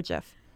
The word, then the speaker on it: GIF: How do you say it, GIF or GIF? – Honestly, I think it changes daily. I think as of right now, I'm going with the hard G, GIF.